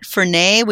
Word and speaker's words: Fernet: And then you accompany that with, and I don't know if we've mentioned it, Fernet, which is spelled F-E-R-N-E-T, Fernet.